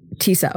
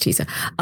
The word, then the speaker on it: Tisa: Uh, I don't know how to quite pronounce her first name, but more known as Mia Farrow's sister. – Yep, and, Tisa Farrow. Yeah. – Tisa. Okay, it is Tisa.